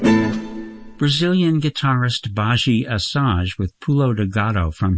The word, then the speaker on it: Badi Assad: Her name is spelt B-A-D-I-A-S-S-A-D, Baji Asage.